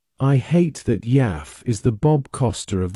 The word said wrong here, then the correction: Yaffe: I couldn't get his name right. It's Yaffe, by the way.